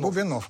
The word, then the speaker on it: Бубенов: И после всего этого, я так не делаю монтаж, позвонил, своим путаю ударение, Бубенов, да? Или Бубенов? – Бубенов. – Бубенов, да, писатель.